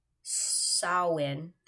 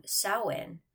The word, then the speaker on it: Samhain: On the night of October 31st, they celebrated Samhain. That's how it's pronounced, you guys. S-O-W-I-N. Samhain, when it was believed that the ghosts of the dead returned to the earth.